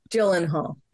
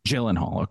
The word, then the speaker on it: Gyllenhaal: Jake Gyllenhaal. Is that how you say it? – Gyllenhaal. – Gyllenhaal. Okay.